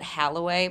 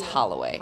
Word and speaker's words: Holloway: A lot of people will pronounce it Holloway, but it's Holloway. It's H-O-L-L-O.